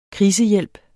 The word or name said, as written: krisehjælp